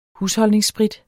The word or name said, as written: husholdningssprit